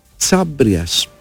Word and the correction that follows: Χαβρίας: Χάμπριας. Δεν ξέρω πώς ακριβώς διαβάζεται αυτό. Και τι είναι ακριβώς. Είναι, συγγνώμη, Χαβρίας. Αθηναίος στρατηγός του 4ου αιώνα. – Χαβρίας. Πολύ Χαβρίας, ωραία. Λοιπόν.